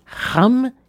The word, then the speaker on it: Cham: Cham, en vérité, se prononce Ram et ça veut dire chaud, brûlant, bouillonnant.